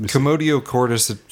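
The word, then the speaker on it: commotio cordis: Commodio cordis, C-O-M-M-O-T-O. – Oh. Yeah. – And then C-O-R-D-I-S.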